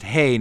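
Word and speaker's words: hey (ה): If it lands on the H-E-I, which apparently is pronounced hey now that I see it there, the player takes half the pot, rounding up if there's an odd number.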